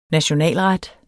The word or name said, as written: nationalret